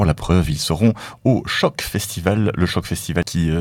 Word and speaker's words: Sjock: Donc Choc, déjà ça s'écrit S-J-O-C-K, autant le savoir,